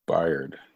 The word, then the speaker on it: Byard: Kevin Bard, is it Bard or Byard? I forget. I don't know how to freaking pronounce his name. – I thought it was Byard.